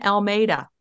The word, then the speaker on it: Almeida: Anne Almeida.